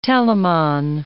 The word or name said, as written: telamon